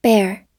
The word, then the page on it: bear: verb